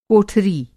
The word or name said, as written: कोठरी